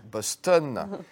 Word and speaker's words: Boston: Boston. On on prononce à, l'anglaise parce que dans les, dans les films québécois, ils appellent euh, cette ville Boston ou dans les vieux films français.